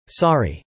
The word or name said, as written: sorry